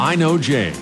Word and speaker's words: INOJ: She goes by the stage name I Know J. It's spelled I-N-O-J. Here is I Know J with her cover of Time After Time.